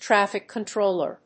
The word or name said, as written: traffic controller